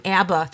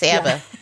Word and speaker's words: ABBA: And you know, we were corrected yesterday; they're actually called ABBA. Here in the Midwest, we always call them ABBA. That's three syllables. ABBA.